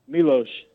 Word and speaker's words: Milos: Uh, so my father is of Serbian descent, so his dad when they came over here, his name was Milos, so he named me after his father. – Fantastic. And by the way, is it pronounced Milos or Milos? – Milos. – That's what I thought.